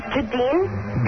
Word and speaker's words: Jahdene: This is Jadine. – Who? What's your name, honey? – Jadeen. – Gunga Dean? – No, Jadeen. – Jadeen? – Yes. – Jadeen, how do you spell that? – J-A-H-D-E-N-E. – Jadeen.